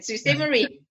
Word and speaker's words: Sault Ste. Marie: So I guess my question would be, back when you just had that one house in Sault Ste. Marie, I'm probably saying it wrong. – No, it's actually exactly the way you said it, Sault Ste. Marie.